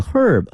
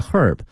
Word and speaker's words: herb: 者烧香啊，在夏天 burning incense，那么说在中国有很长的历史，同时这个香呢它的原料呢从这个 herb H-E-R-B 哈，从这个草药里面获得的。但同学们注意，这个草药呢不同的国家哈，就美国和英国它的念法是不一样的，英国人会把这个 H 这个 herb 这个和这个音呢给念出来 herb，但是美国人啊会把这个第一个字母 H 给它吞进去，直接念成 herb 啊，所以呢大家听不同国家的人念这个词的时候呢要小心了， herbs herb as wormwood is a magical herb或是 herb 都行哈，是一个神奇的一个草药， that can be made into bowls, food and medicine.